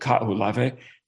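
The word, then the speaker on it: Kahoolawe (Kahoʻolawe): The island's called Kahoolawe, and I think that's the correct Hawaiian pronunciation for my 11 years in Hawaii.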